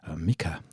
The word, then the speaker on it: Mika: And then from Norway we moved to Finland and Mickey and Lefty, Mika, I beg your pardon, I always pronounce his name wrong, Mika and Lefty, featuring The Chef.